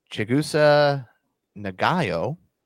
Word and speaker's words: Chigusa Nagayo: And Chigusa Nagayo.